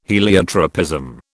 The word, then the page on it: heliotropism: British English